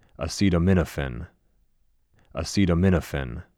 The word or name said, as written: Acetaminophen